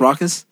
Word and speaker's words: Rawkus: Was that Rawkus Rawkus? – Yes. It, ruckus. Ruckus. – Ruckus. – Ruckus. Ruckus. – Ruckus. – Ruckus. – What ruckus is the correct pronunciation? It's Ruckus.